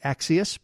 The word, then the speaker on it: Axios: They like to pronounce it Axios, but it's A-X-I-O-S.